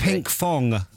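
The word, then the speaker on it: Pinkfong: I actually said Pink Form. – But that's not the right answer. – Which is what they say at the start. It depends how you pronounce it, actually. – It's Pink Fong. F-O-N-G.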